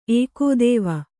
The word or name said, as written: ಏಕೋದೇವ